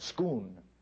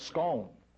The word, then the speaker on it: Scone: I don't know how many of you are aware of literally a stone, a rock, stone of Scoon, or the stone of Scone, some have pronounced it.